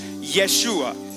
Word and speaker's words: Yeshua: And in Hebrew, it's pronounced Yeshua, which is just like Jesus.